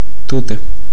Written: Tute

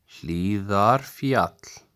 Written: Hlíðarfjall

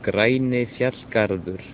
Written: Grænifjallgarður

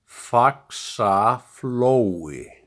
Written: Faxaflói

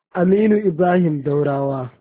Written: Aminu Ibrahim Daurawa

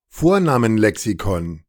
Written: Vornamenlexikon

